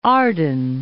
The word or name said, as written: Arden